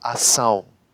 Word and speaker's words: ação: Ausgesprochen wird es aber Ação. Bedeutet, der stumme Konsonant C verschwindet jetzt einfach.